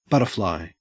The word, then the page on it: butterfly: Australian English